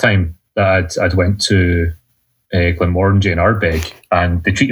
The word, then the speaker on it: Glenmorangie: So Glenmorangie in our big. – Glenmorangie. – Just think orangey, just orangey, orangey, orangey, Glenmorangie.